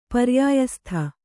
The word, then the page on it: ಪರ್ಯಾಯಸ್ಥ: paryāyastha